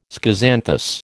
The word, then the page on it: schizanthus: American English